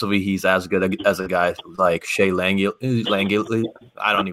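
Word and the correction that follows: Langeliers: I think it's Langilliers.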